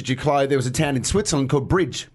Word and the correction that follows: Brig: B-R-I-G. – Yeah. – So we wanted to send someone to Bridge in Limo. To go there first, then go to Limo. – I think that's pronounced Brig.